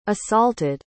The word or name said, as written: assaulted